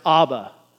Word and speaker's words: Abba: By the way, the word Jesus used when he told his disciples to call God Father is an Aramaic word pronounced Abba. Yeah, like the Swedish band from the 70s, but that's a different thing. Abba, A-B-B-A, which means simply daddy.